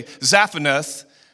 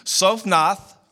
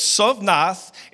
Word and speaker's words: Zaphnath: Now, when we read it, the English in us want to say Zaphnath and Paneah, right? That's how we see it. But this is how it's actually pronounced. Everybody say this with me. Sophnath, That is the Z part of that. That's Sovnath.